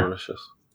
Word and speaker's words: Mauritius: Mauritius. I think that's how you say it. – Yeah. Mauritius.